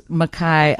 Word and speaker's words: McKay: That's how it's spelled, M-C-K-A-Y. But pronounce Mackay.